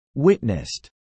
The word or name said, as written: witnessed